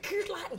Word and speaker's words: Coors Light: You're even saying it correctly. Coors Light.